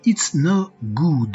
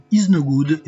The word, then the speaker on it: It's No Good: À suivre dès maintenant un titre de Depeche Mode avec It's No Good de 1997. Il faut bien prononcer le S de It's, sinon ça fait Is No Good et ça n'a absolument rien à voir.